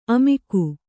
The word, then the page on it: ಅಮುಕು: amuku